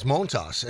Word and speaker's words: Montas: But it had always been pronounced by a lot of people as Montas.